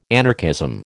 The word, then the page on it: anarchism: American English